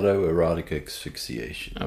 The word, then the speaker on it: autoerotic asphyxiation: Erotica asphyxiation, is that right? Is that how you say it? Autoerotic asphyxiation.